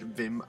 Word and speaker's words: Wim: Wim is spelled W-I-M, but he pronounces it Vim Oudek. So O-U-D-I-J-K.